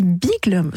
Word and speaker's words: Beagle: Mais je pense que naturellement, la prononciation la meilleure, c'est de le prononcer à l'anglaise, puisque c'est un chien anglais, Beagles, comme les Beatles.